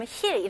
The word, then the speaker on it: heal: 여기서는 EA 발음은 E 발음이잖아요. 근데 조금 더 길게 해주세요. Heal, healing처럼 heal 이렇게 짧지는 않아요. 영어로는 heal 이렇게 고무줄 당기는 느낌으로 heal. Heal. Okay, very good. 이 E 발음을 잘 해주셔야 됩니다.